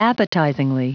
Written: appetizingly

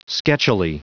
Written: sketchily

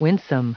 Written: winsome

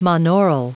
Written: monaural

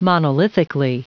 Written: monolithically

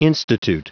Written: institute

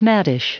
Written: maddish

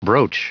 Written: brooch

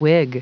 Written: wig

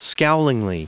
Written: scowlingly

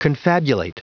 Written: confabulate

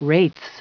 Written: wraiths

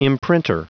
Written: imprinter

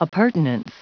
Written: appurtenance

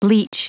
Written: bleach

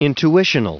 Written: intuitional

